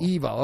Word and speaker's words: EVO: Is it EVO or EVO? How do you pronounce EVO? – EVO. Okay. They just announced the EVO lineup for 2019.